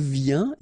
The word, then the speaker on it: vient: Then il vient, exactly the same sound because final T here is not pronounced.